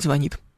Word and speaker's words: звонит: Да, смотри, нам пишет Роман и говорит, что правильно говорить «звонит».